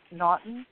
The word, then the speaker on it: Naughton: Naughton. – How do you spell it? – N-A-U-G-H-T-O-N.